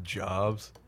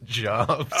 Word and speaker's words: Jobs: I want to go with Jobs. Because it looks like Jobs. – It looks like the word Jobs. And everyone pronounces it Jobs.